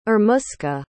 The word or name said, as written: irmuska